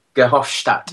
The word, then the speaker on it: Verhofstadt: I think I can never pronounce his name properly. – Verhofstadt. – Yes.